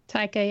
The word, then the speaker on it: Taika Waititi: And we get this very, very different take by director, and I'm going to say his name wrong because I always do. Taika Waititi, is that right? – Taika, yeah. – Taika.